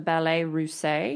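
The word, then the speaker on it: Ballets Russes: There was a famous group called the, I think this is French, so I'm going to pronounce it as such, the Ballet Russe, R U S S E S, basically Russian ballet in French.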